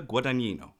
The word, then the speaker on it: Guadagnino: If you haven't guessed it already, our film of this episode is Call Me By Your Name, made in 2017 by Luca Guadagnino.